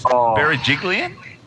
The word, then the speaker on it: Berejiklian: Berejiklian? – Yeah, Gladys.